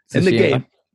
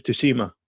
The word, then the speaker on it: Tsushima: How do you pronounce that? Tsushima. In the game, he calls it Tsushima, whatever.